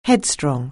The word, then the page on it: headstrong: anglais